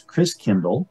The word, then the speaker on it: Christkindl: And the German pronunciation of Christ child is Christkindl, like kinder care, kindergarten. Kind means child, Christ means Christ. And over the years, Christkindl got pronounced Chris Kringle.